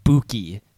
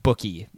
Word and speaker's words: Buki: And also, I did want to say, just in case there was any confusion about it, with Brendan Radley-Hiles, his nickname, it's pronounced Buki, not bookie, like a gambling bookie. It's boo, as in boo, there's a ghost, and then key, as in you're going to turn the key. It's Buki Radley-Hiles.